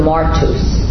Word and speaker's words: martus: Martus or martus. Martus. Yeah, it's syllables on the first. I mean, the accents on the first syllable. Martus. What does that sound like? martyr. Martyr, it is. It's connected to martyr.